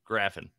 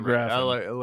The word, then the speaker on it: Graffin: I do know that Greg Gaffin. – Graffin. – Graffin? Did I say that wrong? It's not Gaffin? – It's not. – Greg Graff. Oh, that's. – Wait, am I saying it wrong? – What's your name, Greg? I know you're listening. – No, I'm right. Okay, Graffin. – Okay. – I'm right. Greg Graffin.